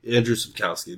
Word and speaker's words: Andrzej Sapkowski: So, yeah, the series is written by the Polish writer Andrzej Sapkowski. – Andrzej Sapkowski. – Andrzej Sapkowski, there you go. – At least that's how I've been hearing people pronounce it.